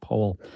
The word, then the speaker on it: Powell: It's spelled like Powell, but it's pronounced Powell.